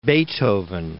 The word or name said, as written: Beethoven